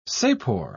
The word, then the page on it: sapor: British English